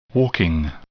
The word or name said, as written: walking